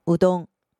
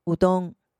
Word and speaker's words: うどん: 这里再顺道教大家几种面类的说法，最常听到的是乌龙面，日文念成乌冬，乌冬，乌冬，而蕎麦面的日文是 soba，soba，soba。